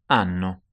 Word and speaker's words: hanno: Hanno. – Which is spelled H-A-N-N-O, but the H is silent.